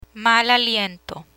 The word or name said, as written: mal aliento